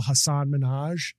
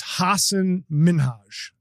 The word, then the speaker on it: Hasan Minhaj: And I've really focused on pronouncing his name right, because I used to always say, like a lot of people, Hasan Minhaj, but it's Hasan Minhaj.